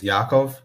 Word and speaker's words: Yakov: But yes, I mean, technically the proper way to pronounce my first name is Yakov, like the A as in like ah.